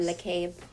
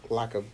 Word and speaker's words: Lacob: LaCabe. – Lacob. Like Jacob.